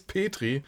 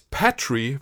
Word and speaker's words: Petrie: Es ist die Tochter, es ist der Schwibschwager, die Schwibschwester, Grace Petrie oder Grace Patrie, wenn man es vielleicht britischer aussprechen möchte.